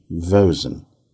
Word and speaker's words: Vosen: It's Rosen with a V, Vosen.